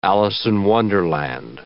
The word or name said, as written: Alice-in-Wonderland